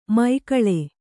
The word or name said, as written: ಮೈಕಳೆ